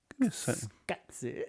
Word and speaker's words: Koyaanisqatsi: But I just instinctively knew there was not a U after that Q, though. – Yeah, because of how I had to pronounce it. Because that would have been... – Because I had to pronounce it. Skatsy.